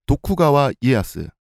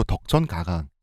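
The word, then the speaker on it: 도쿠가와 이에야스 (徳川家康): 그러고 나서 도요토미 히데요시에 복속됐던 도쿠가와 이에야스, 한국식 발음으로 덕천 가강 이 양반이 도요토미 가문을 뒤집으면서 일본을 재통일하죠.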